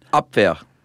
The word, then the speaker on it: Abwehr: Yeah, the Abwehr, I think that's how you pronounce it. You're the one who knows German. – Abwehr. – Is that right? – Yeah.